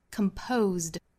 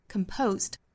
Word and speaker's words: composed: Composed，这是清清浊浊的规律。 Composed，但是在单词尾部，一般我们说浊辅音是要轻化发音，轻辅音要不送气发音，所以呢倒也不能把这个词读成 composed，要读 composed， composed，很轻的收音。